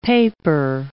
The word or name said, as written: paper